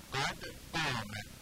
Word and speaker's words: قد قامت: برای اینکه قاف شبیه غین نشه باید قاف رو محکم بگیم قد قامه، سفت رو محکم تلفظ کنیم.